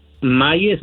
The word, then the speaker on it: Maie: 7730 Maye, se escribe M-A-I-E, Avenue, Los Ángeles, California, 9001.